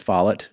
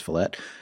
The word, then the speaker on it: Follett: It's by Ken Follett, which I used to think his name was Follett because that's how it's spelled, but it's actually pronounced Follett.